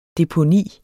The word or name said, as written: DEPONIET